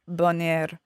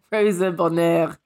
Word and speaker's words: Bonheur: Okay, so I'm going to talk about Rosa Bonheur. Say it right again so that people know who I'm talking about. – Rosa Bonheur.